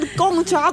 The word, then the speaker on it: Gong Cha: But that's how it's pronounced. That's Chinese, Divian, Gongcha. Those are the words.